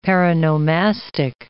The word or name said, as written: paronomastic